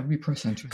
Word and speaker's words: reprocentrism: So, like, I know one that you've spoken about previously, is it called reprocentrism? – Oh, yeah. – Am I saying it correctly? – Yeah, reprocentrism.